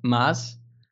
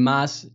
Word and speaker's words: Maas: So my full name is Chris Maas. But for English people, that's quite difficult to pronounce. So I just mashed up some letters and made it sound a little bit more English-like.